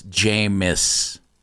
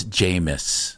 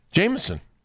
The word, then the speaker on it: Jameis: The quarterback's name is Jameis. – Oh. – It's not an extra syllable. It's Jameis.